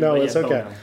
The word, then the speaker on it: Bono: It's a Bono. It's okay. Oh, it's fine. Common mispronunciation. – Bono.